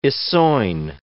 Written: essoin

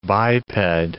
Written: biped

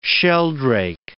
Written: sheldrake

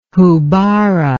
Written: houbara

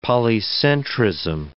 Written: polycentrism

